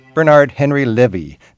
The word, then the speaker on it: Bernard-Henri Lévy: I'm talking with Bernard-Henri Lévy. I'm working on my French pronunciation. Bernard-Henri Lévy.